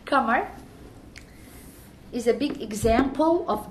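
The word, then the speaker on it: Kamar (قمر): We call her Amar because we don't pronounce ka in Egyptian Arabic. So it's Kamar, which means moon. And we say Amar in Egyptian.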